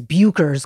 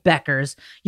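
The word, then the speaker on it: Bueckers: A lot of people think her name is Bukers because of the way it's spelled. It's Paige Beckers.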